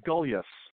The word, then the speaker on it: Gulyas: Gulyas.